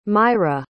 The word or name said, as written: mayra